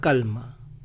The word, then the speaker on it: calm: Este es un dicho que en inglés se dice después de una tormenta viene una calma. Después de, after, una tormenta, a storm, viene una calma, comes a calm, comes a calm, la L no se pronuncia, after a storm comes a calm.